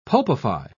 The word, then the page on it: pulpify: British English